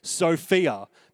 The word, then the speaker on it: Sophia: The word wisdom often in the Bible is a word that I will pronounce this way, Sophia, because that is how it's pronounced in the original text.